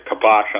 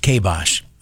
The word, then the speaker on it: kibosh: Yeah, look, when I found out this morning the department hired a relative of yours, I put the kibosh on that real fast just because I don't like you. – I understand that. The word is pronounced kibosh, okay? Kibosh.